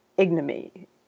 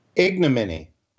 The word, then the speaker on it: ignominy: And I'm going to predict that he leaves office in a state of total ignominy. I can't pronounce that word because I'm tired. But you get the idea. – Ignominy. – Ignominy, thank you.